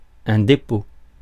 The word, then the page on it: repository: American English; [rɪˈpɒ.zɪ.tə.rɪ]